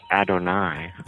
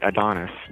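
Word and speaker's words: Adonais: What is the pronunciation of that? – Well, I recently found that it's Adonai. – Oh, Adonai. Adonai? Who mourns for Adonai? – Yeah. Right, but I always called it Adonis.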